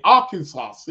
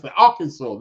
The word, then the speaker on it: Arkansas: Arkansas. Yes, Arkansas.